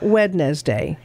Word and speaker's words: Wednesday: The word looks like it should take far longer to say than it does. The middle three letters look like they should be a separate syllable. But the truth is, they sort of get swallowed. They're there because the day is named after the Norse god Odin Aka Woden. – Ah. – Woden's Day. – Well, okay. – And it is pronounced Wednesday, not Wednesday. – You don't pronounce the D.